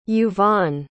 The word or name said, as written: yuvaan